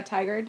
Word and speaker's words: Tigard: Tigard? – Oh, yeah, Tigard.